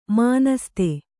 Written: ಮಾನಸ್ತೆ

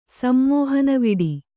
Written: ಸಮ್ಮೋಹನವಿಡಿ

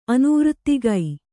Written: ಅನ್ವರ್ಥಕ

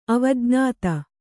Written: ಅವಜ್ಞಾತ

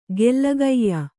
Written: ಗೆಲುಗಯ್ಯ

